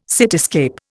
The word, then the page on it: cityscape: Indian